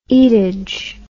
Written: eatage

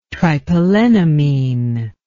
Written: tripelennamine

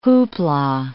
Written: hoopla

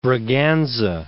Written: Catherine of Braganza

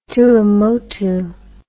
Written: Tuamotu

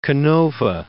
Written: Canova

